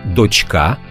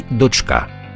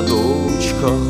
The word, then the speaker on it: дочка: Тож тут варто запам'ятати дочка і донька, донька і дочка. Ось, наприклад, група мандри з наголосом трішки помилилася. Мельника млин на горі, чарівна дочка. А правильно мало б бути дочка.